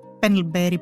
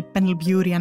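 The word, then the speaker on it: Pendlebury: Ειδικά στους Βρετανούς αρχαιολόγους, τι γνωρίζουμε για τον Πενλμπέρι, Πενλμπιούρι, ανάλογα πώς θέλουμε να τον προφέρουμε.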